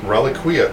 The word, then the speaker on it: Reliquia: Oh man, how do you pronounce that? Relinquia? – Reliquia? – Reliquia. Man, yeah, I like that better. – I think the clown won. – We'll go with Reliquia.